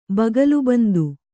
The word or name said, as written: ಬಗಲುಬಂದು